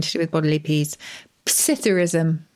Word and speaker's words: psithurism: Is the P pronounced? Should it just be Psithurism? – Well, you can pronounce it either way. That's a good point. Dictionary will give both.